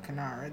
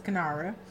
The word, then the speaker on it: kinara: We have the Kinanra, a candle holder. – Yes. – Well, I just pronounce it Kinara. They really strong pronunciations. Kinara. It's Kinara.